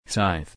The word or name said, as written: scythe